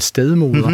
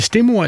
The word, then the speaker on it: stedmor: Hedder det stemor eller st- stedmoder? Jeg tror også, der begge udtaler øh, er acceptable kan, efterhånden. kan høres. Ikke?